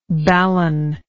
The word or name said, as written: balun